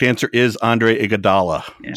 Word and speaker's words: Iguodala: No, it's Iguodala. I screwed that up.